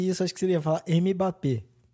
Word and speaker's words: Mbappé: Aliás, só um adendo aqui, você fala Mbappé, né? E eu falo Mbappé, tipo, eu acho que o certo é não falar, né, o M. – O M é mudo.